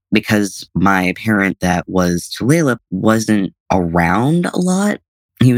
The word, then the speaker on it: Tulalip: Tulalip. – Tulalip.